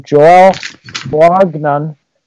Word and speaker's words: Joel Bouagnon: Joel Bouagnon got.